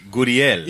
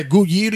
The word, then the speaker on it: Gurriel: Yuli Gurriel. – Yeah, Gurriel.